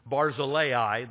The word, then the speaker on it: Barzillai: But I'm going with their suggestion and calling him Barzillai. That's the way they say his name is pronounced.